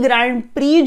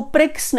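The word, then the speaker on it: Grand Prix: उसके अलावा ऊपर हमने ई ग्रैंड प्री जो इंडिया ऑर्गेनाइज कर रहा है हैदराबाद में, इसको प्रिक्स नहीं बोलते हैं, इसको ग्रैंड प्री ही बोलते हैं, इसीलिए मैं डेलिब्रेटली ये प्रोनाउंस कर रही हूं ताकि आपका प्रोनाउंसिएशन भी ठीक हो जाए।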